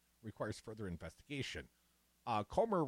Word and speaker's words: Comer: Jim Jordan, who's expected to become the chair of the House Judiciary Committee and other Republicans on the Oversight Committee, Comer, rhymes with Gomer, said Republicans have made connections between the president's son, Hunter Biden, and the president that they believe requires further investigation.